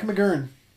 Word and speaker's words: McGurn: Moran had even once tried to kill Capone's colleague, a friend named Jack McGurn. – Did you say that right? – I did. Jack McGurn.